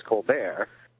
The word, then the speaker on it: Colbert: but that the French pronunciation is Colbert, and he grew up a Colbert.